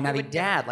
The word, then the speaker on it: Navidad: No, Navidad, like a dad.